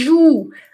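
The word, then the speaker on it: jouent: Estas crianças que estão jogando, brincando, que ju, atenção, terceira pessoa do plural. Eu falo, eu falei ju, eu disse ju, eu ditei ju, mas se escreve juente, entendeu?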